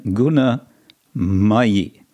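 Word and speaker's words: guṇamayī: We have the word maya with long a in both cases, and then guna mayi, and that's a short a.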